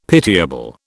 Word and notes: pitiable: British English

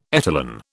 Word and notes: etalon: British English

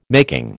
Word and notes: making: American English

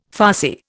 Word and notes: farcy: Indian

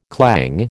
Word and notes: clang: American English

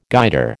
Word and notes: Guider: American English

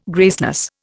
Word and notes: graceless: Indian

